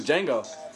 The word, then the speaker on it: Django: He was Django. – Yes, he was. – Yeah, that D is silent.